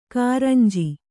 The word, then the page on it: ಕುರಂಜಿ: kuranji